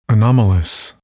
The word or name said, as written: anomalous